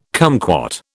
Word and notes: cumquat: British English

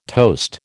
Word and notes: toast: American English

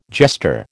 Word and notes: jester: American English

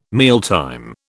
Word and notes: mealtime: British English

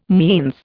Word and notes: means: Indian

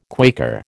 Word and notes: Quaker: American English

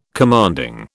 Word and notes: commanding: British English

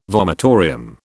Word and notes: vomitorium: British English

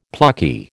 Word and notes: plucky: American English

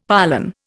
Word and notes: pollan: Indian

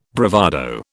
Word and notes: bravado: British English

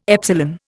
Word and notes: epsilon: Indian

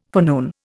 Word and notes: forenoon: Indian

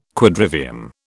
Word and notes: Quadrivium: British English

